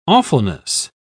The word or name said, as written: awfulness